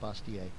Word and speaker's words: Bastiat: Some people pronounce it Bastiat. Frederick Bastiat, B-A-S-T-I-A-T.